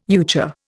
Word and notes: euchre: Indian

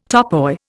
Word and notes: topoi: Indian

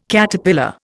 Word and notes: Caterpillar: Indian

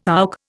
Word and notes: AUC: Indian

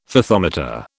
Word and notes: Fathometer: British English

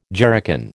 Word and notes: jerrycan: American English

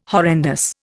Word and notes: horrendous: Indian

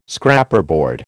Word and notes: Scraperboard: American English

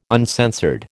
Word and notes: uncensored: American English

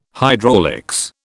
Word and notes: hydraulics: British English

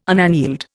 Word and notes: unaneled: Indian

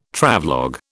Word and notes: Travelogue: British English

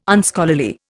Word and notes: Unscholarly: Indian

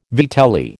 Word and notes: vitelli: American English

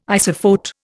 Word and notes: isophote: Indian

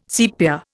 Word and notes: sepia: Indian

